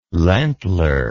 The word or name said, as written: landler